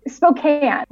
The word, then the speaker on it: Spokane: And then you got, I assume then you found jobs in Spokane. Spokane? Yeah, Spokane? So he, how do you say it? – Spokane.